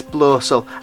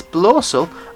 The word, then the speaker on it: Blaucel: They're on Insta, they're on Etsy, it's Blossel. And I know it's a bit of a nice avant-garde style name, so it's spelled BLAUCEL. That's Blossel on Instagram and Etsy.